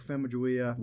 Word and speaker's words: Famuyiwa: We've been saying it wrong. Because I love this episode. I absolutely love this episode. – Okay, well, how do we say it then? – Famuyiwa.